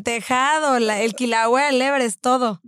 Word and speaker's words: Kilauea: El Kilauea, o cómo se dice. Creo que sí es Kilauea, creo que sí es Kilauea, sí es Kilauea.